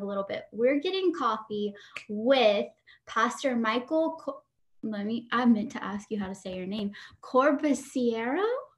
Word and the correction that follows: Corbusiero: Close. Corbusiero. – You don't say the I.